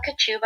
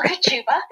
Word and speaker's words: Kochuba: Oh, sure, sorry, you mentioned my name is Angela Kochuba, and I do pronounce it like a sneeze. Kachu, Angela Kochuba.